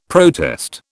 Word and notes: protest: British English

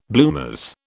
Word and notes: bloomers: British English

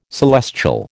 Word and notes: celestial: American English

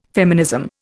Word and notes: feminism: Indian

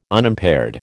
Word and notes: Unimpaired: American English

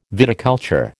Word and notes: viticulture: American English

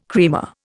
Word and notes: creamer: Indian

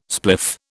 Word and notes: spliff: British English

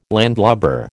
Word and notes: landlubber: American English

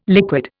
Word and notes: liquid: Indian

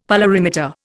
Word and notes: polarimeter: Indian

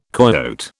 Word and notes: coyote: British English

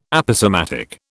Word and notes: Aposematic: British English

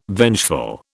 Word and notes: vengeful: British English

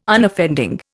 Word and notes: Unoffending: Indian

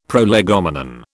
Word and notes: prolegomenon: British English

